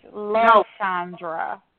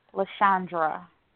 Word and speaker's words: Lashondra: No, it's Lashandra. – Spell it, honey. I'm not going to. I done wrote it three times. Uh-huh. – L-A-S-H-O-N-D-R-A. Lashandra.